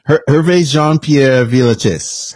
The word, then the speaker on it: Hervé Jean-Pierre Villechaize: Hervé Jean-Pierre Villechaize.